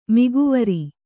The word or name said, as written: ಮಿಗೆವರಿ